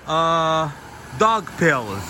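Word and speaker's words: Daugavpils: and My Thoughts on Dog Pills. So, the city I'm in, guys, to pronounce it properly, you pronounce it dog pills. Yes, if you say dog pills, they'll know what you're talking about.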